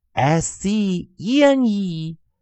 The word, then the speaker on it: scene: 还是要抓住我们的诀窍，S C，先去看后方的元音字母是不是 E，再去决定它的发音。在 actual 更多练习的生词中，同学们也可以多加练习，去查一查它的读音以及解释。好的，我们再把这三个生词再带一遍：S C L E，scale，scale，scale，规模比例。 S C E N E scene scene scene 场面情景，S C O P E scope scope scope 范围视野，也希望同学们多加练习。